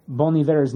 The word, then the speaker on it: Bon Iver: Did you know how to actually pronounce Bon Iver's name? It's not Bon Iver. It's Bon Iver. – Bon Iver? – Yeah, fucking Frenchman. Because for years, I pronounced Bon Iver's name as Bon Iver.